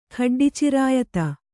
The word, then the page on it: ಖಡ್ಡಿಚಿರಾಯತ: khaḍḍi cirāyata